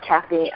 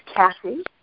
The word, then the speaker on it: Cassie: It's actually not Kathy. Let me spell it. It's Cassie, C-A-S-S-I-E, and the last name is Solomon, S-O-L-O-M-O-N.